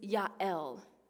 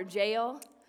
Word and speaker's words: Yael: It says, most blessed among women is Yael. Okay, I grew up in Georgia, and so we always called her Jael. But let's not butcher her name. Yael. Everybody say Yael.